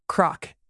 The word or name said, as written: crock